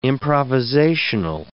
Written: improvisational

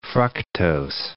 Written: fructose

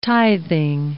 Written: tithing

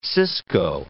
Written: cisco

